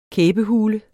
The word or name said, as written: kæbehule